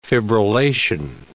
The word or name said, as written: fibrillation